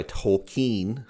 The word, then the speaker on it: Tolkien: And kind of what we touched on was a long defeat, something that was mentioned by Tolkien, as I think it's properly pronounced, since Everett and Tom said it that way.